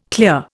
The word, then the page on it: clear: Indian